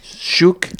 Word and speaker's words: Ciuc: Ça s'écrit C-I-U-C. Chouk.